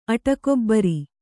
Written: ಅಡಕೆಬೇರು

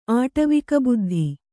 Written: ಆಟವಿಕಬುದ್ಧಿ

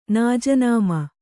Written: ನಾಜನಾಮ

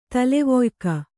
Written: ತಲೆವೊಯ್ಕ

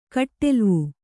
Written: ಕಟ್ಟೆಲ್ವು

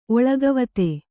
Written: ಒಳಗವತೆ

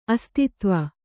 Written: ಅಸ್ತಿತ್ವ

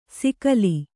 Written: ಸಿಕಲಿ

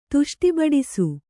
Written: ತುಷ್ಟಿಬಡಿಸು

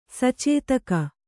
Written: ಸಚೇತಕ

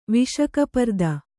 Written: ವಿಷಕಪರ್ದ

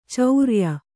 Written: ಚರಿಯ

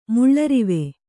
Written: ಮುಳ್ಳರಿವೆ

